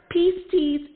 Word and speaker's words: pistis: And in Greek, the word faith is the word pistis. It's spelled P-I-S-T-I-S, and it's pronounced pistis.